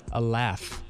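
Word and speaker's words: Aleph: It's Alaph. – Sounds like a laugh.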